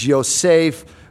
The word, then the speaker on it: Yosef: Joseph's name is Yosef.